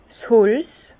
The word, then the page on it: 's Holz: Bas-Rhin; Herrlisheim